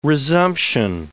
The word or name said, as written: resumption